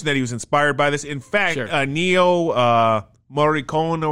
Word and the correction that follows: Ennio Morricone: Ennio Morricone.